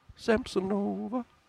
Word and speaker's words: Samsonova: Samsonova is how you actually pronounce that.